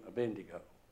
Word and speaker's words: Abednego: And Azariah became Abednego.